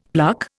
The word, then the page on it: pluck: Indian